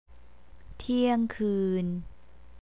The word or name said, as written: tiangkeun